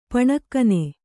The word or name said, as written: ಪಣೆಗಣ್ಣ